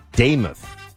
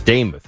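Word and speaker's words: Demuth: And Buck, so it's Lisa Daymuth, I am told. You know, Daymuth.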